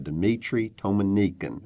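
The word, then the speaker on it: Dmitri Tiomkin: It stars John Wayne, Robert Stack, Jan Sterling, and Claire Trevor, and one of the great music scores by Dmitri Tomanikin.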